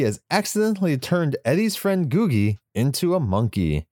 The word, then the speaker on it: Googie: Come back, little Googie. That's how you pronounce it.